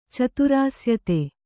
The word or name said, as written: ಚತುರಾಸ್ಯತೆ